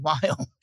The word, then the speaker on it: Viome: I said, V as in Victor, I-O-M-E, Viome. And he said, well, that's not Viome, it's Biome.